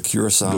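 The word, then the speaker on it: Curaçao: I don't know if it's really related to the none of us are saying it right. – Liquor, but Curacao, the Beach Boys, how it's pronounced.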